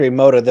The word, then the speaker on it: Emoto: And I think it's Dr. Emoto. I hope I'm saying that right. He did the water. Do you know this? Is that his name, Dr.? – Yeah, Dr. Emoto.